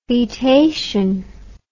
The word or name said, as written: foetation